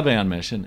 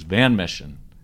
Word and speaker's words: Van Mission: But when I asked Joe Walsh why he didn't call it the Van Mission, as in the mission, he was very determined that it was Van Mission to put the accent on the van.